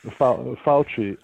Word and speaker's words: Fauci: Fauci. And Fauci. – I think, by the way, that is probably going to turn out to be worst case scenario. – Probably. – And in fact, Dr. Fauci has spoken of it as if it were worst case scenario.